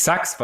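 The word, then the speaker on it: SUX: Ja, wir sagen natürlich Sachs, weil das merkt sich jeder.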